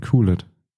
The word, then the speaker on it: QLED: OLED und QLED. Oder QLED, wenn du es richtig aussprechen willst.